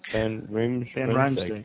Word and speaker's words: Van Riemsdyk: Van Riemsdyk, yeah.